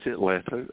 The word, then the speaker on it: Atlanta: I know we're not supposed to pronounce the T. Is Atlanta, right? – It's Atlanta, Atlanta. Yeah, most people don't. – Don't pronounce that T.